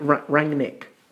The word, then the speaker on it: Rangnick: Ranganak. Have I said that right? – Rangnick. – Rangnick.